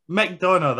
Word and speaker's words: McDonough: McDonough, the Irish Madonna. McDonough. Irish.